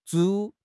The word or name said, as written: 拄